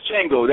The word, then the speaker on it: Django: It's Django, that's correct. – I have not. I know I'm mispronouncing it.